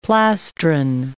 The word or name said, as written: plastron